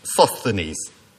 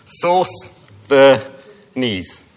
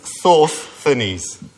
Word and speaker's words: Sosthenes: You perhaps think Sosthenes. But according to the Greek scholars, it should be pronounced like this. And can we have the slide, please? Yeah. Source the knees. And they're not my knees, okay? Yeah, so it should be pronounced source the knees.